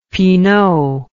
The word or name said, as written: pinot